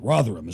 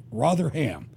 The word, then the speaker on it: Rotherham: Rotherham is how they say it. Rotherham.